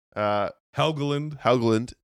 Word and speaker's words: Helgeland: Helgeland.